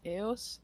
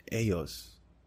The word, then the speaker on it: ellos: pronounced is Eos. – Eos.